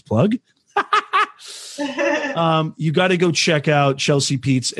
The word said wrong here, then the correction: Pites: You know, it's actually Pites. Like it rhymes. – Pites.